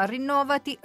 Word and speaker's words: Rinnovati: Rinnovati Rinnovati. Credo di aver giocato bene sull'accento, ma lo domandiamo a chi l'ha ideato, ed è Fiorino Iantorno, responsabile progetto cittadinanza studentesca per l'Università.